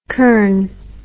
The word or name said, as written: curran